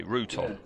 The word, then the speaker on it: Rutan: So it's spelt Rutan, but everyone in his show says Ruton. – Yes. – So what do we go? I think we go with Ruton. – All right, we'll go with what they say, Ruton.